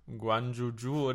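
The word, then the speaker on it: Guanyu Zhou: Janju Ju? Guanju Ju oder.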